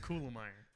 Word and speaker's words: Kulemeier: Kulemeier.